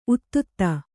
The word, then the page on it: ಉತ್ತುತ್ತ: uttutta